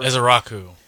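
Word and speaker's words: Ezeiruaku: Malachi Lawrence, your rookie, probably not going to do anything because you got Rashawn Gary, Donovan Ezeorike or whatever. – Ezeraku. – Yeah, Ezeraku.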